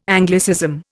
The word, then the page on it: Anglicism: Indian